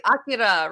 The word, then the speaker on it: Akira: I try to pronounce it the Japanese way, Akira, right? – Oh, yeah, that is right. Yeah.